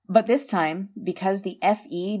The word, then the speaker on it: Fe: So I dug deeper and learned that the chemical symbol for iron is pronounced as Fe in the US and UK, but is the spoken letters in Canada.